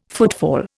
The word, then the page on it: footfall: Indian